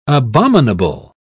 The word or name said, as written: abominable